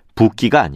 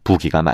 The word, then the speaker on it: 부기: 그리고 이 표현의 발음 역시 붓기가 아니라 표기 형태와 같은 부기가 맞죠.